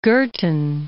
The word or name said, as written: Girtin